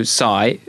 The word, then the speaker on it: Tsai: Jack Ma, our listeners have probably heard of him, and his Alibaba co-founder, Joe Tsai.